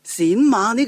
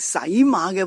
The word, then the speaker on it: 冼马: 而冼马呢亦都是担任辅助工作的其中一个人。我们就明白为什么晋武帝会找学问又高、品性又好的李密呢去担任冼马这个官职去辅助太子了。如果过去你将冼马读成洗马的话呢，其实不足为奇的噃，你不是唯一的一个啊，因为数百年以来啊，好多人都将这个官职呢读成洗马的。